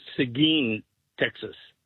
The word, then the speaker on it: Seguin: The little town is actually pronounced Seguin, Texas.